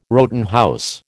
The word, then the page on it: Rowton house: American English